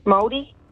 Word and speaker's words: Māori: Maori? – Māori? Oh. And you pronounced it beautifully.